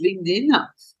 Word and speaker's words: LinkedIn: Ah oui, oui, parce que l'intérêt de la newsletter, c'est quoi C'est que LinkedIn, et je suis obligée de bien prononcer LinkedIn parce que je suis avec toi, mais pas à la française.